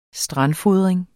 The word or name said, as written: strandfodring